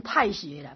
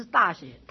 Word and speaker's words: 大学: 然后接下来说做大学，这个应该念成太学了，不是大学，太行，太行了。